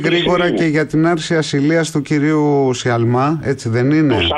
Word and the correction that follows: Σαλμά: Σαλμά, συγγνώμη για το γιώτα που εμφυλοφόρησε στην προφορά μου.